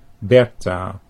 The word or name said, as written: Berta